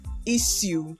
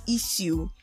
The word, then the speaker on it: issue: Then again, the word spelled I-S-S-U-E, which people pronounce as issue, is not issue. It is issue. I-S-H-O-O-S. Issue, not issue. It is issue. That's the pronunciation of that particular word.